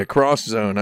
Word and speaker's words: X-Zone: Yeah, cross-zone, I believe, is how it was supposed to be pronounced, right? – Yep, it was.